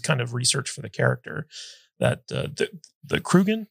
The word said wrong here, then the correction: Kurgan: is that how you pronounce Kurgan. – It? – Kurgan.